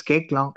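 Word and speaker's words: Koechlin: AK, that's how you pronounce the name. It's Kekla. – I don't know, man. Seriously, I used to pronounce it Kalki Kochlin till, I think, last year. And then I realized it's something different.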